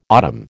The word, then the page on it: autumn: American English